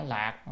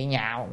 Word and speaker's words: lạc: Chữ này đọc ái lạc là hạnh phúc à, và tại do chữ ái là yêu thích rồi, cho nên mình không đọc chữ nhạo mà đọc là chữ lạc, lạc âm lạc, lạc là vui mừng, tức lời nói ra cái điều khiến cho người ta yêu thích vui mừng á.